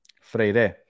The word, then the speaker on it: Freire: Freyer gets injured, getting on planes. What's going on, guys? – Freire. Mike V says you pronounce it Freire.